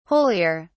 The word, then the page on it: holier: British English